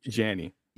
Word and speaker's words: Janney: Is it Janie? – I'm gonna correct you on both of those. – Yeah, both. Okay. – Thank you. And Allison Janney. – Janney. Yes. Okay.